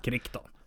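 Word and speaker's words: Crichton: Tyvärr så, den här, eh, jag bara konstatera att jag, han, eh, hur uttalar man det? Michael, Krichton, jag Michael, vet. Krikton, Krishton. Krikton.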